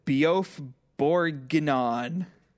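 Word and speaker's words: bœuf bourguignon: I was learning how to cook, Beauf Bourguignon.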